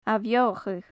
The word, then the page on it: ath-beothachadh: gaelic